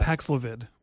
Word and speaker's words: Paxlovid: I said that right? Paxlovid? – Yeah. Paxlovid.